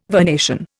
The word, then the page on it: vernation: Indian